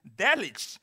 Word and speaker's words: Delitzsch: You have D-E-L-I, and then you have T-Z-S-C-H. How do you pronounce a name without vowels in it? Delitzsch.